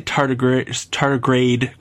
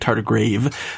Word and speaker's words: tardigrade: I did not say tardigrade correctly. I kept calling it tardigrave.